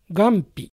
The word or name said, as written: Gampi